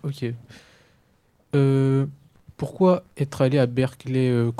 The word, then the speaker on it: Berklee: Tu es effectivement passé par Boston dans la Berklee College of Music, mm-hmm. si je prononce correctement. – Très bien.